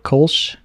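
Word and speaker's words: Kölsch: Is that how you say it? Kolsch? – Yeah. – Gosh. Yeah. – Yeah, you got it.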